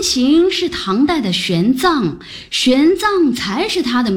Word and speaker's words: 玄奘: 玄奘法师？我一直以为读作玄奘呢。 – 玄奘的奘上面是强壮的壮的繁体字字形，下面是大小的大，上下合起来是壮大。 – 是的，藏的意思正是壮大，用于人名如唐代的玄奘。